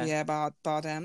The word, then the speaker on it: Javier Bardem: Javier Bardem.